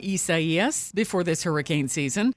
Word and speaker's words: Isaias: It says the pronunciation is Isaias, which would have made things a lot easier for meteorologists and the rest of us who have to say the name a lot.